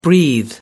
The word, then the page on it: breathe: American English